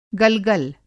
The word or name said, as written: गलगल